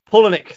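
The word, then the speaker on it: Palahniuk: Palalala. I can't pronounce his surname. Polonick, that's it.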